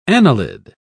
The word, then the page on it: annelid: British English